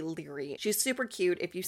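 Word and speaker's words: Laoghaire: It's L-A-O-G-H-A-I-R-E. So it's Leary, spelt a different way.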